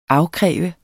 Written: afkræve

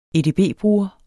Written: edb-bruger

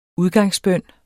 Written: udgangsbøn

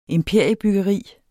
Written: imperiebyggeri

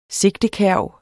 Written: sigtekærv

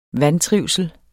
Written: vantrivsel

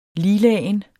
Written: liglagen

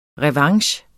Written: revanche

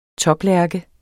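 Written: TOPLÆRKE

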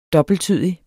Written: DOBBELTTYDIGE

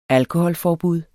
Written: alkoholforbud

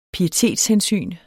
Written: pietetshensyn

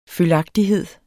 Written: følgagtighed

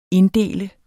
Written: inddele

